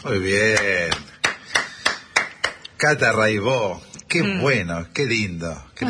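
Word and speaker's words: Raybaut: Cata Raibó. Se escribe Raibaus, métanse, búsquenla, Rai con Y, Baut con B larga. Catalina Raibaut, Raibó se dice, y bueno, cotidiano son 10 canciones, hay un documentalito muy lindo.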